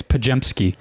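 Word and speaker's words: Podziemski: There's like a G in there, like a J. You say it like a J. It's Pajemski.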